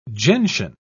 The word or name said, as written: gentian